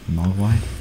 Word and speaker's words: Mogwai: Mogwai.